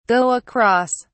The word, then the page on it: go across: British English